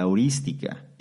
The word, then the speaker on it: heurística: heurística. Heurística, con H y con acento en la primera I.